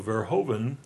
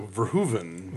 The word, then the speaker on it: Verhoeven: Also, the Dutch OE sounds like an English OO, so Verhoeven rhymes with Pershoeven. – So Verhoeven. – Verhoeven.